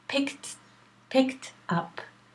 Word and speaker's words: picked up: The next verb in the past is picked up. Pick tap. If you have trouble, say tap. It's a phrasal, see? Picked up. With a K, the ED is always a T, pronounced as a T.